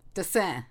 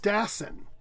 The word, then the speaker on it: Dassin: Anyway, his name, the first name is spelled J-U-L-E-S. The last name is D-A-S-S-I-N. – Dessin. – Oh. – Mitch wait. – Ross, my ex-husband, loved him, but I know I'm saying it wrong. – It's Jules Dassin. – Exactly, Jules Dassin.